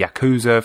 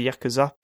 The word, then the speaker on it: Yakuza: From what I understand, the U in the middle of Japanese words isn't pronounced, but at the same time, I don't exactly sound Japanese when I say Yakuza, so I'm sure it doesn't really matter.